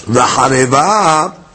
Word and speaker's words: V'hareva: V'hareva. Is that the right pronunciation? Yes. V'hareva.